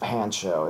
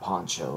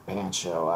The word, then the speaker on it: Pancho: Goes by the name of Pancho, and not Poncho like you'd think it'd be pronounced, but Pancho.